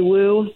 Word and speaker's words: Woo: Wu, W-O-O is how you spell that last name.